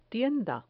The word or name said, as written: tienda